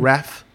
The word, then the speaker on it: Ralph: Ralph Fiennes. No L. Ralph. – There's no L in his name? – Ralph Fiennes. – Oh, I thought it was Ralph.